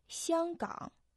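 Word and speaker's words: Сянгкан (香港): Сянгкан. Первый иероглиф «сянг» произносится в первом тоне, означает «аромат», «ароматный». Второй иероглиф «ган» произносится в третьем тоне, означает «залив», «бухта».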